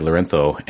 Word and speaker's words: Lorenzo: Why do I say Lorenzo? And it's because, that's how he pronounces it. And so just sort of trying to be basically polite of, you know, trying to say his name the way he says it.